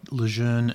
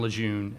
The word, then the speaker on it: Lejeune: And we should pause maybe for a brief moment here and recognize the pronunciation Lejeune that is out there, right? And some of the descendants of John Lejeune continue to try to tell the Marine Corps today that Lejeune is the proper French pronunciation here.